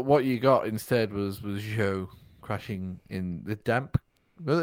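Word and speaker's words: Zhou: His name's not Zoo. It's Joe.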